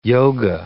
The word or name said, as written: yoga